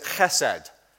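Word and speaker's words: chesed (חסד): You pronounce it chesed. Some people put a C in front.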